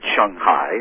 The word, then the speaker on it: Shanghai: A little more Shang rather than Shang, but Shanghai is fine.